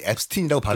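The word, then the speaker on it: Epstein: 앱스틴은 본인 스스로 자기 이름을 앱스틴이라고 발음해.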